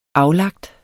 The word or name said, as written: aflagt